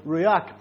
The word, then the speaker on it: ruach: And now, across the Old Testament Hebrew, we have the word ruach, pronounced as so.